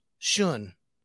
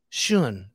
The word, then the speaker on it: Schoen: It's got the exact same, but I think it's called Schoen, is how they say it. My wife was from Germany. Goes, Schoen. Schoen. That's how she says it.